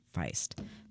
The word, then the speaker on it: Feist: Yeah, Feist. That's how you pronounce that.